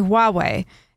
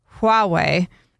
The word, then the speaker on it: Huawei: If you can say Juan, you can say Huawei. It's the same thing. There's no reason the transliteration of, and they put the symbol in there, is Huawei. There's a reason that's Huawei and not Huawei. The H is there for a reason.